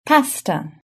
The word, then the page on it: pasta: British English